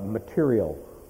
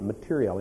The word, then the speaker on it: materiel: And most people, whenever I use that word, they always correct my pronunciation. I'm not talking about material, okay? I'm talking about materiel.